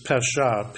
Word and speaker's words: pesha: It's pesha, P-E-S-H-A, with the accent on the A.